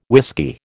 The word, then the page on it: whisky: American English